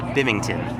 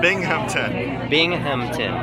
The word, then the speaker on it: Binghamton: Hey, this is Tim Nordwin from the band OK Go, and you're listening to WHRW Bimington. Is that right? – Bimington. – How do you say it? – Binghamton. Yeah. – Oh, I spelled it wrong. How do you spell it? – B-I-N-G.